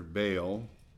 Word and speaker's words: Baal: That's a long A there, Baal.